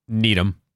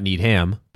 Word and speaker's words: Needham: If it's a two syllable town that ends in H-A-M, it's an um. If it's more than two syllables, it's a ham. – Interesting. Okay. – Yep. – So Dedham, Rainham, Framingham. – Needham. – Needham. – Not need ham, but Needham. Mm-hmm.